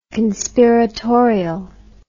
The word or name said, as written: conspiratorially